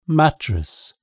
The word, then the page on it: mattress: British English